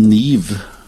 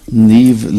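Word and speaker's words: Niamh: Det staves alltså Niam. Det här förnamnet, men det uttalas tydligen av Niv. – Jaha. – Niv Lynn.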